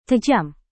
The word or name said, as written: tajam